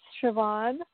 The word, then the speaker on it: Siobhan: Are you impressed that I know how to pronounce Siobhan? – I really think that's cool. – Thank you. I went to elementary school with a girl named Siobhan, and, you know, it's a Gaelic name, so it's not spelled as you might expect it to be spelled.